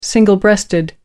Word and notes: single-breasted: American English

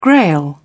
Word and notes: grail: British English

